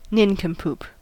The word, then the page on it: nincompoop: American English